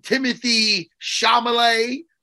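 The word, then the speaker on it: Timothée Chalamet: Josh Brolin, Timothy Chalamet.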